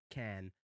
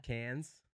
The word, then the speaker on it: Cannes: Well, Americans say can, but the way you said it, I was like, is that in the north of Queensland in Australia? – Cannes.